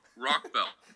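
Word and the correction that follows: Rochell: Yes, Isaac Rochelle.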